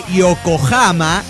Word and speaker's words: Yokohama: La H suena como J, Yokohama, ese Hama se escribe con H pero es con J, se dice con J, Hokkaido, y la J suena como Y, es decir, Gaijin, Romaji o Meiji.